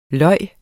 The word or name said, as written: lyv